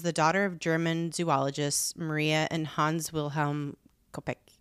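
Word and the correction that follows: Koepcke: Kopke. I'm like not good with pronunciation of foreign names. – It's only because I heard it. – Kopke.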